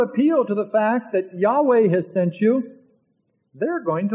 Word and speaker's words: Yahweh: This new name for God in Hebrew is represented by four consonants, Y, W, YH, WH, and is often pronounced in English as Yahweh, YHWH.